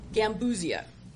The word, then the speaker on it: Gambusia: Gambusia? – Gambusia.